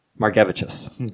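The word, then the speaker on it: Margevicius: But you're supposed to say it like more cabbages. So it's Margevichus.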